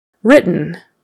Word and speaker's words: written: T-H-I-N-G, thing, E-E. Want, want, spelled with an A, say it like a short O. Written, is it written down? Those two T's in the middle become very soft.